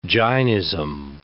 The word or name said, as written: Jainism